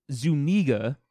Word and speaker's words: Zuniga: and he said, and finally, it's Zuniga. Accent on the second syllable.